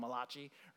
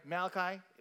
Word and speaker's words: Malachi: Maybe you've heard of Malachi as the Italian prophet by people who don't know how to pronounce Malachi. They call him Malachi, right? Uh, either way, right, Malachi, we're not as familiar with him.